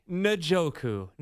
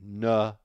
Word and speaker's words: Njoku: Njoku. Njoku, everybody. – Pretend there's a U and an H after the N. Njoku.